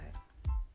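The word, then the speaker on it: (G)I-DLE: They were also trainees before, but the group, their name starts with a G, but they prefer not to pronounce the G, so they say Idol.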